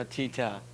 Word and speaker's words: Tita: As he said, like, we had to call her Tito and stuff like that. Or did I say that wrong? – Tita. – Tita. Yeah. Oh, yeah. Yeah, I got a nickname throughout the week. Yeah, Tita. We had to call her that.